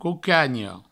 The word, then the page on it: cocanha: coucagno